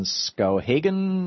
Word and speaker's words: Skowhegan: So, 866-97-THOM, our telephone number, Scott in Skowhegan, Maine.